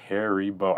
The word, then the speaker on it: Haribo: And it is officially Haribo.